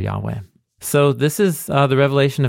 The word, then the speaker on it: Yahweh: Which you're saying is most likely pronounced Yahweh. – Yeah, the earliest evidence we have points in the direction of Yahweh.